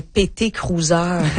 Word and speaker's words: PT Cruiser: Oh, un PT Cruiser.